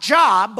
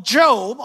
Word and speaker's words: Job: But if you are not familiar with the story of Job, it's not Job, but Job, although they're spelled the same.